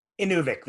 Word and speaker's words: Inuvik: Also, how do you pronounce it, Inuvik or Inuvik? – Inuvik, the second one, yeah.